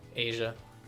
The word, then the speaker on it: Aja: Is that how you pronounce that? – Asia.